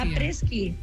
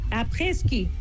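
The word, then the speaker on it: après-ski: Apreski o apreixi? Com es pronuncia? – Apreski. Apreski. És que és una paraula francesa. Després d'esquiar és après.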